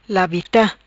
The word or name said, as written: Labita